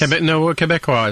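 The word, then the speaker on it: Québécois: Quebecois is probably the proper pronunciation, yeah.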